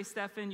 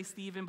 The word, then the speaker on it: Stephen: And he's standing there while Stefan, I say Stefan, you could say Stephen, but I'm a Warriors fan, so it's Stefan.